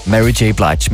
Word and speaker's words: Mary J. Blige: Vi havde på et tidspunkt en kollega her på Nova, som troede, at hendes navn udtaltes Mary J. Blige.